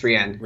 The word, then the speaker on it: Rian: So that was really cool, just seeing you know, the three announced with Ryan, or I should say it's now pronounced, I think it's pronounced Rian, isn't it? – I believe it's Rian.